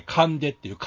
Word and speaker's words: 神出: はい、神出っていう、神が出ると書いて神出って言うんですが。